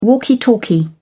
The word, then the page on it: walkie talkie: British English